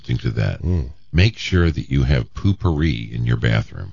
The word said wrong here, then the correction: potpourri: First of all, that's not how you pronounce it. – Poopery? – No, it is poopery. It's a product. – Yeah, we have it in our house. – It's to stop your... – It's potpourri.